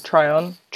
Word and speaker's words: Tryon: So it's a mini series based off of a novel known as Harvest Home, just Harvest Home, by Thomas Tom Tryon. – Tryon. – Tryon.